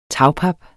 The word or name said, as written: tagpap